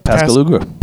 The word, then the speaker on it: Pascagoula: The carrot-headed aliens who visited a pair of night fishermen on the Pascagoula River. Is that how you say it? – Yep, Pascagoula.